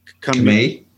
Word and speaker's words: Kamei: It's a character, I'm going to get the name wrong, Levi Kamei. – Kamei. Sorry, yeah. Thank you so much. I appreciate that. – Yeah. – What was there? Does that suggest that the run of Levi Kamei's character is going to be more short-lived and eventually Alec Holland will reassert himself as the primary character of Swamp Thing?